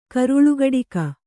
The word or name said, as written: ಕರುಳುಗಡಿಕ